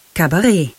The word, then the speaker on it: Kabarett: Der Name Kabarett kommt aus dem Französischen und wird daher Cabaret ausgesprochen.